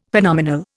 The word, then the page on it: Phenomenal: Indian